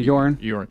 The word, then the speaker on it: Joran: And we started being introduced to this person, and how do you say his name? Vandersloot, but the first name is it? – Joran. – Is it Joran Vandersloot? – Huh? – I think it's Joran. – Is that how you say it? Joran. Yeah. Joran. Yeah. And so I know, you could say it the best. I couldn't remember it. – No, you guys are right. Yeah, Joran.